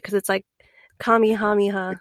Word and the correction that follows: Kamehameha: Kamehameha. – Kamehameha. yeah, we're doing good.